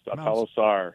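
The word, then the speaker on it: Sawtalo Sar: And this was on, forgive my pronunciation, was it Mount Satalosar? Is that correct? – That is correct. Satalosar. It's about a 9,000-foot peak.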